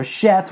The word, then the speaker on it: Seth: So Seth, or Sheth, where he's sometimes pronounced, took the place of Abel.